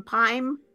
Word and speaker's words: Pym: I don't know how to pronounce it. That PYM Pime, the one where they make the pretzels bigger. – Oh, the Pim's Test Kitchen, I think.